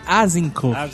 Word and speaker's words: Azincourt: Agincourt. – Mas dizem que é Azincourt.